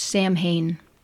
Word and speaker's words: Samhain: Incidentally, Samhain is spelled S-A-M-H-A-I-N, as though it ought to be pronounced Samhain, but its Celtic pronunciation is Samhain.